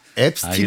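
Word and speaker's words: Epstein: 앱스틴은 알겠습니다. 본인 스스로 자기 이름을 앱스틴이라고 발음.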